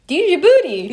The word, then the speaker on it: Djibouti: So what's the capital of, I'm going to say this wrong, it still makes me laugh, I think it's pronounced Djibouti. – Djibouti.